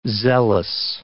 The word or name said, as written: zealous